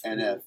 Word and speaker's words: NF: That's how you say it, just NF? – NF, yep.